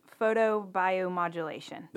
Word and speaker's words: photobiomodulation: But the technical term for it is photobiomodulation.